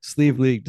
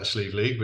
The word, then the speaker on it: Sliabh Liag: And you will not get this unless you look up the pronunciation, Sliabh Liag Distillers in Donegal, Ireland. So, welcome. – Thank you. Well done, man. You got the pronunciation is a little difficult with that Sliabh Liag, but you nailed it.